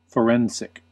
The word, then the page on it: forensic: IPA: /fəˈɹɛn.zɪk/; IPA: /fəˈɹɛn.sɪk/; IPA: /fɒˈrɛn.sɪk/; IPA: /fəˈrɛn.sɪk/; English